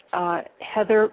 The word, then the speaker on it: Heather: And this is from my website, which is Heather McRae. My name is spelled H-E-A-T-H-E-R.